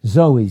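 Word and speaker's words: Zoe (ζωή): Zoe, Z O E, long E, Z O E, Zoe, eternal life.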